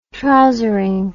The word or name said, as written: trousering